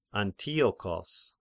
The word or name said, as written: Antiochos